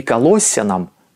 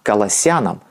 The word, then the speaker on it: Колоссянам: Кстати, насчет ударения здесь меня не поправляйте. Не Колоссянам, а Колоссянам. Русский орфографический словарь Российской академии наук под редакцией Лопатина. Также не Ефесянам, а Ефесянам. Ударение на суффикс в этих словах применялось и в старинных славянских текстах.